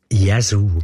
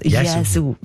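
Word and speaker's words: Yazoo: Jesu. – Jesu. – Gell? Jesu.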